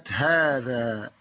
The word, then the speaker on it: هذا: من الحروف التي تنطق ولا تكتب منها الألف في كلمة هذا.